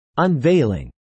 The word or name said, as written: unveiling